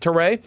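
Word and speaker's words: Turay: But, like, Josh Sweat, Harold Landry, this guy, Kimoko Ture, am I saying it right? – Ture.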